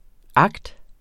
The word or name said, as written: act.